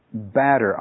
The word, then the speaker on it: Badr: Badar, I would say is the way we pronounce his name, is B-A-D-R,